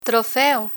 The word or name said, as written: trofeo